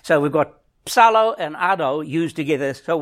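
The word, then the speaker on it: psallo: That's the word from Psalm, P-S-A-L, but it's P-S-A-L-L-O. And the O is a long O. It's got a little dash along the top of it. It's psalo.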